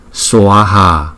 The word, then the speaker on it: Swaha: À, chữ Swaha chúng ta đọc rõ ràng ra bởi vì chữ A có dấu gạch ngang trên đầu thì chúng ta đọc là Swaha, Swaha.